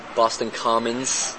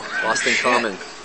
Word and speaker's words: Boston Common: It's not Boston Commons. It's common. We're real particular about how we pronounce words, and... – It's not Boston Common? – No, Boston Common.